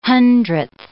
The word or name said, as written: hundredth